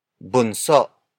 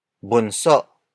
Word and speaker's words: bunso: Bunso. Bunso. Bunso is spelled as B-U-N-S-O.